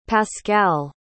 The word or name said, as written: pascale